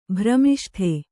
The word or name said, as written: ಭ್ರಮಿಷ್ಠೆ